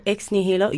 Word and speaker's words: Ex Nihilo: And I found out Ex Nihilo, you do pronounce it like that.